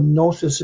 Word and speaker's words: gnosis: They both have a letter that's not pronounced. But the combination, like lasagna, is GN. So it's kind of a nya. So gnosis is supposed to be gnosis.